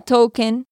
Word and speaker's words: Tolkien: En este momento, uh, Tolkien, ¿sí lo pronuncié bien? – Sí.